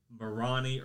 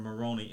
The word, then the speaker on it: Moroni: And then at one point in 1823, he saw this angel, the angel Moroni or Moroni, M-O-R-O-N-I. I'm not trying to pronounce it like moron.